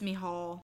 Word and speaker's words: Michal: Google says that it's Michal.